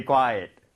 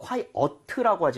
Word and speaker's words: quiet: 이거 quiet라고 하지 마시고요. 네. 저는 추천하는 거는 이를 그냥 생으로 발음하는 거예요. at에 가깝게. quiet, quiet. 이런 식으로 한번 해보세요.